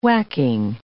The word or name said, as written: whacking